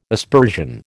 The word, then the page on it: aspersion: American English